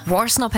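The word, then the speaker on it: Worsnop: Warsnop hätte ich jetzt auch gesagt, aber ich finde diesen Anfang so mega.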